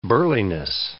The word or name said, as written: burliness